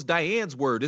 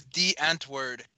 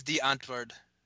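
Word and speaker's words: Die Antwoord: Is that how that's pronounced? I thought it was Diane's word. Is pronounced De Antwoord? – I think it's De Antwoord because it's supposed to be like the answer in Afrikaans.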